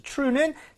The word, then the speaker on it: true: True가 돼야 됩니다. 약간 치읓 같죠? 그 이유는 TR이 충돌이 돼서 그래요. – Yeah. – 그럴 때는 R 발음이 제대로만 해주면 됩니다.